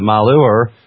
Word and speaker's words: Malheur: And then in addition to that, I've also dropped in, I think they pronounce it the Malheur, M-A-L-H-E-U-R, Malheur perhaps, National Forest page.